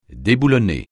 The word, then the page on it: déboulonner: French